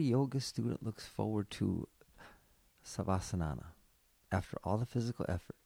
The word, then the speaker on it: Savasana: Savasana.